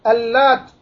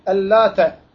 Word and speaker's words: El-Lat: Alimler diyorlar ki El-Lat iki türlü okunur. Bir El-Lat denen şeddesiz, T'nin şeddesiz okunuşu var. El-Lat'e اَفَرَأَيْتُمُ اللّٰتَ Şiddetli olan okunan var.